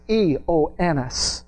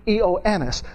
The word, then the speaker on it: Ioannis: And John's name is Ioannis. That's how you say John in Greek, Ioannis.